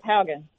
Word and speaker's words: Haugen: So when we look at this woman who's coming forward, is it Haugen? Is that the right correct pronunciation? I'm not trying to. Is that right? Is her name Haugen? – I believe that is correct. Yeah, Frances Haugen.